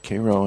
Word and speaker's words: Cairo: Yeah, pronounce that Cairo, and we won't do a shibboleth on you.